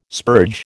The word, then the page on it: spurge: American English